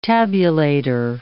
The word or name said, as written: tabulator